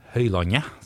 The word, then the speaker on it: Høylandet: Så jeg hadde bodd først en del år på Høylandet, eller Høylandet, som det skal uttales vel egentlig.